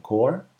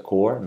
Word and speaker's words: Corps: Pero bueno, Hal Jordan descubre que es parte de esta asociación, The Green Lantern Corps, y así se pronuncia Corps, no Corps. Por favor, pronúncienla correctamente.